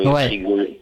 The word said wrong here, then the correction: Prigojine: Prigogine. Ou, J'écorche peut-être un petit peu. – ça Non, non, c'est pas mal, Prigogine, ouais.